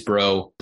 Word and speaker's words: Brault: But it is bro, spelled B-R-A-U-L-T, but it is pronounced bro.